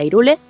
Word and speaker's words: Hyrule: Por ejemplo, el famoso Hyrule Irule, pues ellos decidieron que sería Irule.